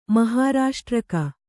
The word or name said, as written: ಮಹಾರಾಷ್ಟ್ರಕ